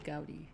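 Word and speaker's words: Gaudí: Gaudi, yeah. – It's spelled G-A-U-D-I for anyone wondering, and we're butchering this.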